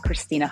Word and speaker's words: Krystyna: Um, all my social media handles is at Krystyna Hutch, K R Y S T Y N A.